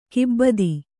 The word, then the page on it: ಕೈಬೆತ್ತ: kannada